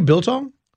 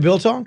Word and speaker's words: biltong: How do you spell that? – B i l t o n g. It's it's jerky, but it's from South Africa.